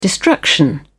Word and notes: destruction: British English

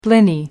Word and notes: blini: American English

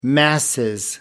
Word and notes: masses: British English

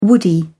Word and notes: woody: British English

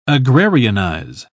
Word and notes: agrarianize: British English

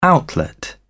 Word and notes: outlet: British English

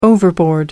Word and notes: overboard: American English

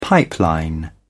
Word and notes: pipeline: British English

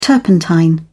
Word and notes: turpentine: British English

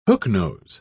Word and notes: hooknose: British English

